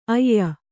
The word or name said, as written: ಅಯ್ಯ